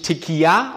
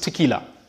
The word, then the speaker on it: Tequila: Es ist nur ein L, daher wird es nicht Tequila ausgesprochen, wie es gerne mal gesagt wird, sondern es ist Tequila.